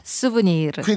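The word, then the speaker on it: souvenir: 수브니르.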